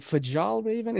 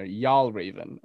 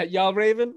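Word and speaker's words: Fjällräven: I was like, how do you pronounce it? Is it Fajal Raven? And he's like, say it like a Southerner, Y'all Raven. I'm like, oh yeah, yeah you know, so yeah, Y'all Raven.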